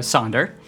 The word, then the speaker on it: Sander: Uh, but yeah, to take a step back, uh, my name is uh, Sander, to take the uh, the Dutch pronunciation, and I am uh, the audio director here at KeokeN Interactive.